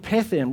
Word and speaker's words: pethen: The other one is a word which is spelled M-I-P-H-T-A-N, and it sounds like pethen. It comes from a root word called pethen, which is P-E-T-H-E-N.